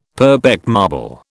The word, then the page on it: Purbeck marble: British English